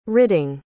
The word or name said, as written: ridding